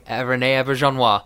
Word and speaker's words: René Auberjonois: René Auberjonois.